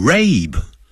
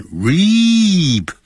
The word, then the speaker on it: reap: 这个字可不要念成 rape， rape R-A-P-E 叫强奸了，所以念成 reap， E E 的音不是 A 的音。